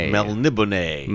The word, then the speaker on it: Melniboné: Melniboné.